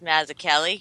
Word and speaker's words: Mazzucchelli: It's actually pronounced Mazzucchelli. – Oh! – It depends on what the creator, how he says it. Oh. It could be Americanized, so he could say Celli, but it is pronounced Kelly.